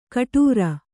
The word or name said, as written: ಗಡುರ